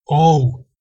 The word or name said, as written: ou